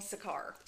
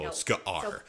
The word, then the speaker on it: Ska'ar: It makes it easier for me to call him Sikar. – Sikar. Well, it's spelled S-K-apostrophe-A-R, yeah, so Ska'ar.